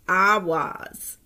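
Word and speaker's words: Ehwaz: And the letter E is pronounced Awaz.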